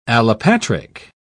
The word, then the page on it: allopatric: British English